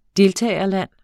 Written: deltagerland

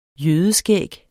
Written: jødeskæg

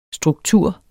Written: struktur

